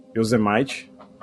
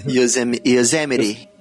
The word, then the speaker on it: Yosemite: naquele parque Yosemite. Eu tô falando a pronúncia errada aqui. – Yosemite.